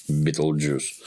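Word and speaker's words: Betelgeuse: Бетельгейзе. Вот она читается по-английски как Битлджус.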